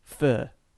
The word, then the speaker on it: pho: Which is fur, which is the bowl of soup that you get, which is spelled P-H-O.